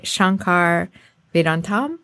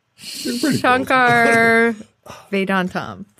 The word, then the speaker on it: Shankar Vedantam: And it's hosted by Shankar Vedantam. I said it wrong. Shankar Vedantam. But he says it quicker than that because it's his name.